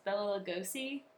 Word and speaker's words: Bela Lugosi: But anyways, so one of the things that I thought was really interesting was Bela Lugosi. – Mm-hmm. – Is that how you pronounce his name? – That's it.